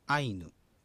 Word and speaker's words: アイヌ: 二つの音節から成り立っているそのアイヌ、アイとヌという二つの音節から成り立っているアイヌ、人間っていう意味の言葉があるんですけども、これは第一音節が平音節なので、愛の部分にアクセントが置かれます。アイヌということですね。